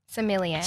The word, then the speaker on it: sommelier: Did you have to like get dressed up being a Silomare? How do you say it? – This is why you don't make fun of other people who can't say it, mate. – Yeah, that's cool. – Silomare? – Sommelier.